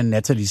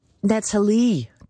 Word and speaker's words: Natalie: Og nu er hun jo altså også Natalie. Trump udtaler det jo sådan overvejende på fransk. Natalie Harp.